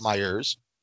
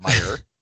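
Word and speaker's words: Meijer: So I walk across the street to Myers, because we're from Michigan and it's pronounced Myers, not Meyer.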